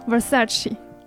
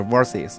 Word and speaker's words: Versace: 为因为因为因为就是记得以前看一个美国电影里边吧，就是有那种暴发户，忘了哪个电影，暴发户就说我买的 Versus，然后大家就咦。就是因为他的那个英文英文发音好像是叫 versus 是吧？ – 好像是，但是实际因为它不是英它不是英语牌子，所以不能用英语发音。